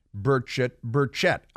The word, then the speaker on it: Burchett: Burchett. I go with Burchett. Hear different pronunciations on the television news.